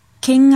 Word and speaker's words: 見: 比如参观的意思 k e n a g u k e n a g u k e n a g u 写成日语的汉字是。看见的“ 见” 的繁体字加一个学习的“ 学”，见学。 ken a g ken a g ken a g 在训读的时候，读 miru mieru 或 miseru，在 mi 的后面加宋假名。